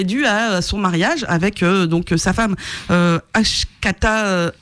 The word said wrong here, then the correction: Akshata: Akshata, excusez-moi, j'écorche son prénom,